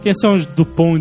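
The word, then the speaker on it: Dupond: No Brasil eles vieram com o original que foi lá de fora, do original mesmo, que é Dupont e Dupont. – É Dupont com T e Dupont com D, mas você não pronuncia a letra final, então...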